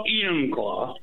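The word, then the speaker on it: Enumclaw: Emmonclaw, Washington. Am I saying that right? – Well, Emmonclaw.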